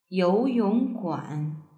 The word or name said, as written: youyongguan